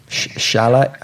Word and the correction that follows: Szalay: So it's David Soloy, apparently. – Soloy? – Yeah. That's how you say the name, Soloy. – Oh. – But it is spelled S-Z-A-L-A-Y.